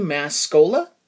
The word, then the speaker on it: Mascola: To find my books, go to either Amazon or Barnes & Noble online and search my name, which again is Andy Mascola. My last name is spelled M-A-S as in sugar, C-O-L-A as in Coca-Cola, and uh, buy a couple of my books.